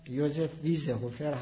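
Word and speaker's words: Josef Wiesehöfer: بعدش هم در قرن بیستم کتاب های زیادی منتشر شده از جمله کتابی که همین آقای ویزهوفر که تلفظ اصلی اسمش هم یوزف ویزهوفر هست،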